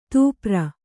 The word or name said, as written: ತೂಪ್ರ